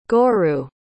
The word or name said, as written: gorou